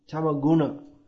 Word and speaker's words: tamaguna: Tamagun means that what is that? Tamotva jnana jangvidhi. In Bhagavad Gita, Lord Krishna states that from tamagun, tamaguna, actually, I should say tamaguna is more correct pronunciation.